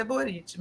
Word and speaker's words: Boric: E tem aqui o ACGF dizendo que não é Boric, é Buriti. – É, provavelmente. – Provavelmente, né? – Mas a gente pode, gente, pronunciar Boric. Eles pronunciam nossos nomes de uma maneira mais bizarra do mundo. Então a gente está livre. Boric, porque deve ser do leste europeu, a pronúncia correta é Boric.